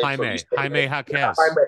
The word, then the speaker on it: Jaime Jaquez: Jamie Jaquez, I think that's how you say his name. – Jaime Jaquez.